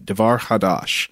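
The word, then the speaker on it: Davar Chadash: The CH is the phlegm-hocking back-of-the-throat sound, not like CH in cheese, so I hope I said that right, Devar Hadash,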